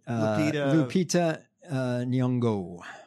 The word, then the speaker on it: Lupita Nyong'o: Lupita Nyong'o.